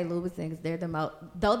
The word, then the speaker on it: Louboutin: Number two, Louboutin? Is that how you say it? – Yes. – Louboutin. – Louboutin.